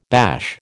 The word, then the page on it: bash: American English